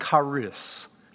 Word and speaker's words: charis: The word that's translated thank in verse 12 is the Greek word charis. charis. In our English transliteration, we might spell it C-H-A-R-I-S, charis.